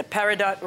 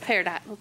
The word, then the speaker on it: peridot: Okay, I see your list, a peridot ring. – Mm-hmm. – Is that how you pronounce that word? – Peridot.